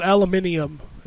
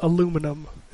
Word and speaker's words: aluminium: Apparently you want to call it aluminium. It's just aluminum.